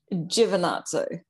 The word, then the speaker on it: Giovinazzo: We just had a huge thing back and forth about me saying her last name incorrectly. – Giovinazzo. – It's beautiful. I'm just butchering it like an American, you know, like we have no culture.